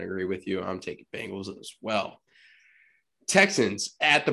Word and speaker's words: Bengals: Bengals, really? The Tiger, Bengals, orange stripes, Bengals. Yes. Okay. Now that I have the image of a bagel out of my head, now I can say it correctly.